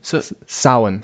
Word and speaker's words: Samhain: It started as a Celtic festival called Samhain? – Samhain. – Samhain, right? – Samhain.